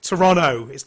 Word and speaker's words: Toronto: Yeah, but you don't pronounce the T, do you? The second T. It's Toronto.